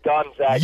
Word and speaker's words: Gonzaga: I know it's not Zaga because it's not the Zogs, they're the Zags, but what is the proper pronunciation? Is it Gonzaga? – It is Gonzaga. – Yes! – Gone. – Yes, it is gone.